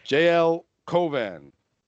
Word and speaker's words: J-L Cauvin: But JL Cauvin. – Perfect. Nailed right. – it. Yeah, perfect.